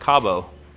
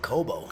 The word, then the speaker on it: Cobo: You said you saw them at Cabo. – No, I saw them at Cobo. – Cobo. I said it wrong. Yeah, C-O-B-O.